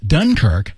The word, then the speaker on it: Dunkirk: I watched Dunkirk last summer.